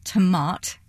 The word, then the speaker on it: Tomat: T-O-M-A-T. – Okay. – So it's a bit like tomato, but with the O off.